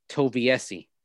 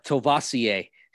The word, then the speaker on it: Toviesi: And poor Paul Toviesi, Shanahan couldn't even pronounce his name properly when he did his draft press conference. He got up and said, Paul Tovassier.